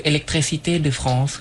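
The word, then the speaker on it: électricité de France: electricité de France ครับ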